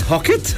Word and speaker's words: Phuket: It's Hocket. It's PU. It's PH. – It's PH, yeah. – PH. P-H-U-K-E-T. P-H-U-K-E-T.